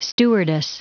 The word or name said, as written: stewardess